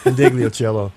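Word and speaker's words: Ndegeocello: And before that, Michelle Ingiocello. Did I pronounce that correctly? I always Indigliocello.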